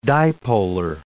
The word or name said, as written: dipolar